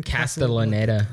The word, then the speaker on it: Castellaneta: Dan Castellaneta.